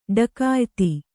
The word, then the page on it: ಢಕಾಯಿತಿ: Kannada